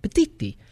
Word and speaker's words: Petitti: Well, if I'm going to pronounce it right, it's Italian name, Petitti. Tony Petitti is now going to be their president of sports and entertainment effective August 17th.